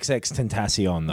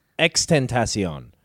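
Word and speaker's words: XXXTentacion: It's not XXX Tentacion, though, is it? – It isn't it? – It's X-Tentacion. – But there's three X's. – There's three X's, but you don't pronounce all three.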